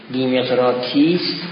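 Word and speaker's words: ديمقراطيس: زیمقراطیس هم بعضی ها نوشتن، اینجا دیمقراطیس نوشت، مهم نیست، چون زی جوری تلفظ میشه که مثل داره، یعنی زبان میاد سر صنایای بالایی میخونه، اونجوری تلفظ میشه، گاهی دار شنیده میشه، لذا میگه دیمقراطیس.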